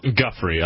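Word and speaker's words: Giuffre: And apparently you said, her last name is pronounced how? – Guffrey. I've heard Guffrey? – It pronounced as Guffrey. – So the I is silent? – I suppose.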